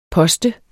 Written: poster

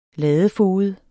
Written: ladefoged

